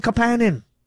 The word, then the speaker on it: Kapanen: Nobody's ever called him Kapanen. Now all of a sudden we're calling Oliver Kapanen Kapanen. Why are we putting the wrong emphasis on the wrong syllable? – Hey guys, it's Dan from Kingston.